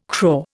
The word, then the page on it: craw: Indian